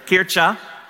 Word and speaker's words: Kirche: When it was translated then into English, there really wasn't a word that really met that, so they found a German word, which I have people that speak German tell me how to pronounce it because I totally blew it first service, but it's K-I-R-C-H-E, Kirche, or Kircha,